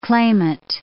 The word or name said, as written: clamant